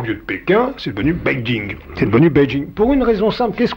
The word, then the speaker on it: Beijing: Si bien que la traduction se lit pour lire, Beijing, comme on doit le prononcer, il faut le Beijing. Alors là, si vous le prononcez avec l'accent anglais, c'est parfait.